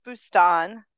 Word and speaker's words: Boustan: I didn't say it right, did I? I said Boustan, and that's like Mississippi way of saying it. – Well, I'm not really particular. It is Boustan.